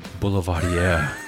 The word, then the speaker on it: Boulevardier: A Boulevard? – Boulevardier. Say it right.